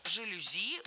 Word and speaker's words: жалюзи: Ну а все французские слова, как мы знаем, имеют ударение на последнем слоге. И чаще всего эта особенность сохраняется и при заимствовании. Поэтому жалюзи ударяем последний слог.